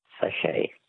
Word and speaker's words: sachet: Words that end with a silent T. Which word for a small sealed pouch literally means little sack in French? – Sachet. – A sachet. S-A-C-H-E-T.